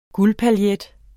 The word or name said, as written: guldpaillet